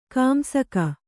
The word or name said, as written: ಕಾಂಸಕ